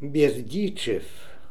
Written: Berdichev

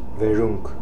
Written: Veroonk

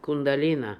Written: Kundalina

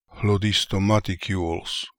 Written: Hlodistomaticules

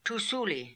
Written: Toosooly

